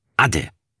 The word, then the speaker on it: Ade: Hier sagt man zum Abschied Ade, mit Betonung auf der ersten Silbe.